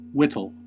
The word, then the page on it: whittle: American English